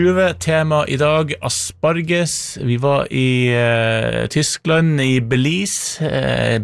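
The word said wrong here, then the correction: Beelitz: Belitz, kanskje riktigere uttale, Belitz i Deutschland.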